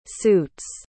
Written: suits

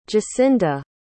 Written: jacinda

